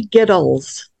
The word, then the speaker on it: Gitles: My name is Tony Giddles. – Giddles. – Rhymes with Skittles.